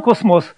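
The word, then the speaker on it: Cosmos: Популярный журнал «Космос», поскольку во французском языке все ударения на последний слог, то вот он так непривычно будет звучать по-русски. Журнал «Космос»,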